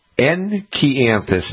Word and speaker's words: Enkianthus: Enchianthus, and it's spelled Enkianthus. Enchianthus.